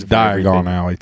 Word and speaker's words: Diagon Alley: Well, if you say it like Diagon Alley, then it's like the word diagonal, which I think is the pun.